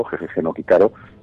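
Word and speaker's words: Jejeje no Kitaro (ゲゲゲの鬼太郎): En este caso lo que se publica es su serie más larga, Kitaro, Jejeje no Kitaro, como se pronuncia en japonés, que es pues una otra obra maestra de este autor.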